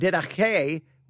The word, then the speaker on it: didache: Is D-I-D-A-C-H-E, didache is how it's pronounced, didache. I want to say didache, and you know why, because we've studied the didache here in this church, but didache, and they definitely use that guttural sound, didache, and then it's not didache with like didache, it's didache.